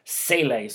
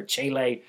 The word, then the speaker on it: Cele: So his last name is spelled C-E-L-E. But he's from South Africa, so I was watching some videos. I mean, he was trying to teach one of his teammates how to say his last name, and it looks like Sam Kello Sele, so I'm going to go with that, or Chele, depending on where you're from.